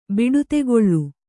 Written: ಬಿಡುತೆಗೊಳ್ಳು

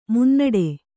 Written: ಮುನ್ನಡೆ

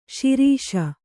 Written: ಸಿರಸ

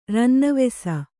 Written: ರನ್ನವೆಸ

